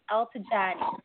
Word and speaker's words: Eltigani: It's Muhja Altajani. So both of the G's are pronounced like a J.